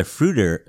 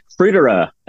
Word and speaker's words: fruiterer: He's a self-proclaimed fruit nerd, and by trade, he's a fruiter. Fruitier? How do you say that? – Fruiterer.